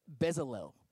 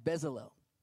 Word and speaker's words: Bezalel: Another person that this happened to in the Bible is, is, a, is a guy named, um, I think he pronounced it Bezalel is how you pronounce his name, Bezalel.